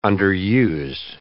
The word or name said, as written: underused